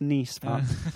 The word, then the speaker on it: Nice: No, it's pronounced Nice part.